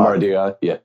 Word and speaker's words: Madariaga: Cardinal Madariaga, probably said it wrong, he gave a talk. – Madariaga, yeah.